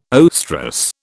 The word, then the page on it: oestrus: British English